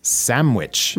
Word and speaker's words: sandwich: But we usually say it like sandwich. – Right. Like with an M.